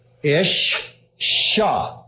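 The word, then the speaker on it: isha: This is pronounced, since it's got a dot there, ish sha. It doubles. Ish sha. There's a dot. It's in the middle of two letters. The sound is repeated in Hebrew.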